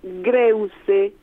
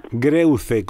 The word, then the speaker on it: Greuze: Sí, Juan Bautista, y te lo pronuncio como se escribe, Greuze, con Z. – Greuze, con Z, sí, ok.